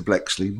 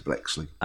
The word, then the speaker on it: Bleksley: At Peter Blexley. My name is spelled a bit unusually. – Yeah. – My surname is B-L-E-K-S-L-E-Y. So I'm at Peter Blexley.